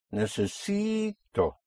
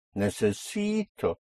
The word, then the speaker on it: necesito: Here we have four syllables. Necesito. You have a vowel at the end, so you push down on the syllable before last, on the penultimate syllable, which makes it necesito. And then you come up on O.